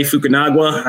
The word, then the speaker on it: Fukunaga: And it is directed by the director of in the maker of True Detective, Corey Fukunaga. I hope I said his name right, Charlie. I know you know names. Did I get that one right? – It sounded good, right?